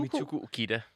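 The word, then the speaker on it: Mitsuko Uchida: og så en, der hedder øh, Christian Zimmermann, og til sidst, øh, hvordan udtaler du hende? Mitsuko. – Mitsuko Ukida. Ukida, ja, præcis.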